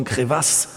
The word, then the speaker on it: Crevasse: Crevasse, Crevasse, französisch ausgesprochen Crevasse.